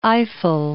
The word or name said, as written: Eiffel